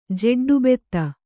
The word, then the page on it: ಜೆಡ್ಡುಬೆತ್ತ: kannada